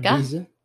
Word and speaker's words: Ibiza: Ibiza? Yeah. You got to say it with the lisp. – Okay. – Because that's how they say it.